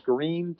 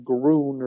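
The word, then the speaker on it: Gruene: Once you get a little further down, closer to a town called Green, Texas, commonly mispronounced by people who aren't from here, looks like Groon or something.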